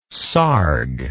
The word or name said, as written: sarg